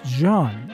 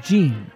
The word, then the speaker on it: Jean: Gene Schwartz was born in Budapest, Hungary in 1878, and his first name is spelled J-E-A-N, so it's probably more correctly pronounced John, as with John Goldkett, but there it is anglicized as Gene.